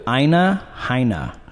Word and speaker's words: Aina Haina: Aina Haina. – Spell that. – It's A-I-N-A-H-A-I-N-A.